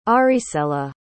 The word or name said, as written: aricela